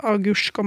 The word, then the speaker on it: agurk: Sånn at ord som agurk og mark blir uttalt agursk og marsk.